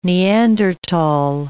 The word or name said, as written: Neandertal